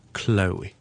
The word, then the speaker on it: Chloe: 咁啊，有啲人读成啊 Chloe 啦，有啲人读成啊 Chloe 啦，有啲人读成啊 Chloe 啦。咁啊，其实咧好简单嘅啫。嘗試讀翻個 L-O-E 先，我哋就咁讀成 Loey Loey Loey Loey Loey Loey Loey Loey， OK，跟住前面好輕輕地加個 k 咁嘅音就得啦。 Chloe Chloe Chloe Chloe。